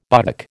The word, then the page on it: buttock: American English